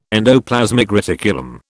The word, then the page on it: endoplasmic reticulum: British English